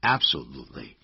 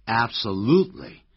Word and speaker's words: absolutely: 你看，有表示肯定的意味的副词，像 surely 确定的、 certainly 的确的、 absolutely 这个字不要念成按照字典的发音 absolutely，其实老外经常是念成 absolutely，重音在第三音节。